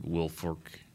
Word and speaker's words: Wilfork: Did Vince Can't, Wilfork, which I would never say his name right, Wilfork.